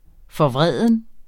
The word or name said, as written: forvreden